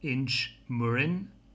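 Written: Inchmurrin

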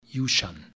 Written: yushan

